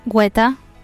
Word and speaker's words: Guetta: Is it Guetta or Guetta? – I mean, so many people say Guetta, but it is spelt as Guetta. Well, maybe not in a Spanish accent, but it's spelled as G-U-E.